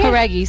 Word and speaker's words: Jauregui: Jauregui, sim.